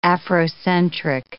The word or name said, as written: Afrocentric